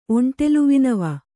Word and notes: ಒಂಟೆಲುವಿನವ: kannada

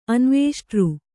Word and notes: ಅನ್ವೇಷ್ಟೃ: anvēṣṭř

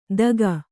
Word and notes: ದಗ: daga

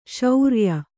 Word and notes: ಶ್ರಾಯ: kannada

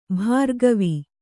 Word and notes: ಭಾರ್ಗವ: bhārgava

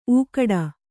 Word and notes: ಊಗಾಡು: ūgāḍu

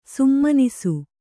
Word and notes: ಸುಮನಸ್ಸು: kannada

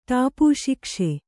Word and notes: ಟಾಪುಶಿಕ್ಷೆ: Kannada

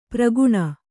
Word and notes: ಪ್ರಗುಣ: praguṇa

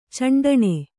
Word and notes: ಚಂಡಣೆ: caṇḍaṇe

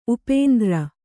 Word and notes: ಉಪೇಂದ್ರ: upēndra

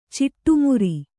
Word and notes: ಚಡಿಮರಿ: Kannada

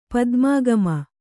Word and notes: ಪದ್ಮಾಗಮ: Kannada

